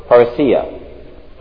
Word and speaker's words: parousia: And it's pronounced for you, parousia, from 3956 and a derivative of 4483.